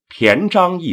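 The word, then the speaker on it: 平章: 骈章一词，平字要读作骈的音。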